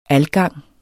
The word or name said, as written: algang